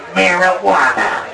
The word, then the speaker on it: marijuana: It's the Mexicans in their marijuana. Marijuana. It even sounds, it's got a J, but you say it like a what?